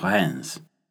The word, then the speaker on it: Reims: And if you're not currently driving, set your Google Maps to the city of Rennes, French pronunciation there. Hang on a second, I'll spell it, because it sounds fairly different from how it's spelled. R-E-I-M-S.